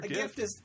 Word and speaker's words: GIF: Gift? – GIF. – I'm still going to call it gift. – It's not even, there's no T.